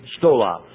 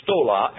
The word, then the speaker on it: stola: Stola. Now, there are two words. One is stola and one is stole. S-T-O-L-A or S-T-O-L long A. Stola, short A. Stole, long A.